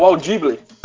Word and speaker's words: Audible: Pra quem não pegou aí a pronúncia maravilhosa do Andes aí, ó, Audible é o Audible.